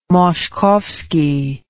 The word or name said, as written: Moszkowski